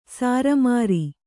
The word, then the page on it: ಸಾರಮಾರಿ: Kannada